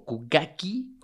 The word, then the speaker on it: okugake: Hacen una, hacen una, mm, un ritual que se llama okugake. El okugake, o kugaki, como se pronunciaría, pero se escribe okugake, es correr mil maratones en mil días.